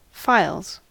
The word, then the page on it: files: American English